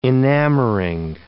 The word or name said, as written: enamoring